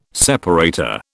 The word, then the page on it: separator: British English